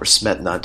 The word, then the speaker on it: Smetana: And that song, it was by Bedřich Smetana, or Smetana, depending on your pronunciation.